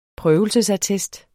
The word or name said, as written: prøvelsesattest